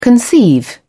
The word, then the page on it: conceive: British English